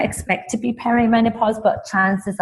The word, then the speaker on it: perimenopause: The first one being perimenopause. Am I saying that correctly? – You are.